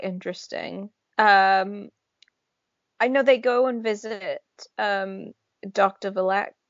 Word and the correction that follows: Valak: at some point. – Valak. – There we go. I always like mess up the pronunciation of his name for some reason.